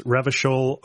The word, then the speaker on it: Revachol: So it's Revachol, Oranje, and Klaasje.